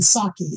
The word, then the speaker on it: Saki: I don't know if you type in Saki, S-A-K-I, if it comes up, I write it in. My name is spelled T-Z-A-C-H-I, and there's not many of me.